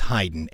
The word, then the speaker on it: Hyden: It's in Kentucky, so it's pronounced Cleveland. It's not. I believe it's Hyden. H-Y-D-E-N. – It's Hyden, Kentucky, dude.